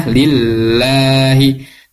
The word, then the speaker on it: lillahi: Nah, terus kemudian lillah lahnya ini tarkik tipis dan dibacanya panjang. Tadi yang saya dengar itu pembacanya pendek. Lillah. Harusnya lillahi.